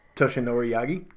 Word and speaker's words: Toshinori Yagi: Toshinori Yagi? – Yeah.